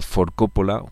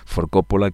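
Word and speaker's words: 4 Coppola: Oye, en el día de hoy, es ya, joder, cómo pasa el tiempo, actúa For Coppola, Cuatro Coppola se escribe, For Coppola,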